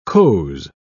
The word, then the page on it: coze: British English